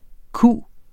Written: q

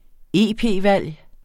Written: EP-valg

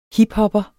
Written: hiphopper

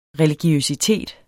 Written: religiøsitet

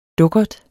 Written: dukkert

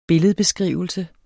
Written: billedbeskrivelse

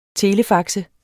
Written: telefaxe

